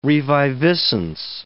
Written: reviviscence

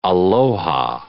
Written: aloha

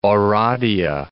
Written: Oradea

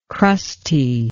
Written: crusty

